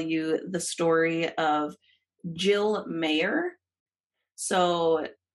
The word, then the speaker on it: Meagher: Her last name is spelled M-E-A-G-H-E-R, but I've looked it up a couple times and it's basically just Mayer, as in like, you know, M-E-Y-E-R in our stretch of the woods.